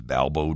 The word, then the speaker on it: Balbo: A few of them, even as they looked up at the street sign, said in what's become a kind of common Chicago mispronunciation, Balboa. Well, it is Balbo,